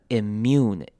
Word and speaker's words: immune: Immune 注意到开头的 I 要念成短母音 I 的声音，这个声音要特别注意，不要念错了， immune I 的声音， immune immune system。